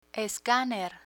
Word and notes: escaner: Spanish

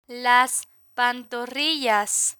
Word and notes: las pantorrillas: Spanish

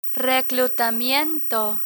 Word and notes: reclutamiento: Spanish

